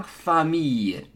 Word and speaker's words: famille: So, city is la ville, and family is la famille, no L's. So, it's not la famille, that is wrong, la famille. So, city, la ville, pronounce the L's, famille, family, skip the L's.